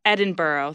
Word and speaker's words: Edinburgh: Edinburgh, thank you.